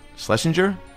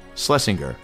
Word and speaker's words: Schlesinger: Schlesinger? Schlesinger. You know what? I think you can hit the G or not hit the G, but I pronounced it right.